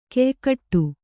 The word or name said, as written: ಖಯಖೋಡಿ